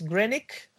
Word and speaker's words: Greenwich: Greenwich, que aquí, que bien pronunciado es Greenwich o Greenwich, que, o sea, somos los únicos que lo pronunciamos nosotros en inglés, los ingleses lo pronuncian mal.